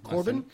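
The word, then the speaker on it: Corbijn: Now, I don't know how to pronounce his last name. Anton Corbin? – Corbin.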